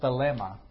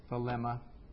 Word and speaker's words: thelema: And it's just a fancy word, the Greek word thelema. It just means will. It's that simple. It's thelema. Thelema actually is how it is. T-H-E-L long E-M-A. Thelema.